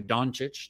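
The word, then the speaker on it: Dončić: It was Luka Doncic. Did I pronounce that right? – Yeah. That's stupid that he's so high.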